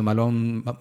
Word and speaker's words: Malone: Eh, baneado, en el caso de Malón, Malón, Malón que se escribe Malone, está baneado, lo banearon de Twitter y de otros lados, ¿cachai?